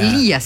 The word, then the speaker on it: Leah: Mira, Lea. – Lía se pronuncia, porque Lía. – L-E-A-H. Lía.